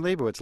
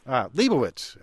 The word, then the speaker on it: Lebowitz: Charlie, were you talking about me laughing and talking or Fran Lebowitz laughing and talking? – I was talking about Liam Neeson or how I don't know how you pronounce her name. – Lebowitz.